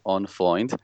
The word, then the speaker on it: On Freund: The correct pronunciation is On Freund. As I said earlier, I'm asking an Irish guy to say an Israeli name or a German name in an Israeli pronunciation.